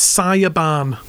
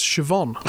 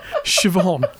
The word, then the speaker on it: Siobhan: So he went, can you spell that, please? So she said, yeah, it's Siobhan. So he said, no, that's Siobhan. Siobhan, do you know? It's an Irish name, Siobhan.